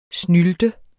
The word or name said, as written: snylte